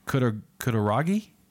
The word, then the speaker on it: Kutaragi: According to former CEO Ken Kutaragi, did I say that right, John? – Mm-hmm.